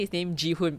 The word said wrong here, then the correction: Gi-hoon: but it should be Gi-hoon, has been just riding on his sheer, like the sheer misfortune of others to get to where he is.